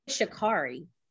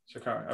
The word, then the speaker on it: Sha'Carri: Sha'Carri, correct me if I said it right. Did I say it right? – Miss Richardson. – I believe it's Sha'Carri. – Sha'Carri, okay.